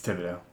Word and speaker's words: Thibodeau: It's Thibodeau. – Now I'm so confused. – There's no... The H is silent.